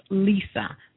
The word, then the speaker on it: Liza: And the good god was named, and I believe it's pronounced Lisa, but it's spelled L-I-Z-A.